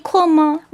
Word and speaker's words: 困: 이제 중요한 거 그냥 쿤이 아니고 쿤. – 쿤. – 약간 쿤자 발음 나게끔 쿤.